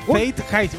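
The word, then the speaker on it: Zeitgeist: Se llama, atención, Faith Haste. Así es como se lee.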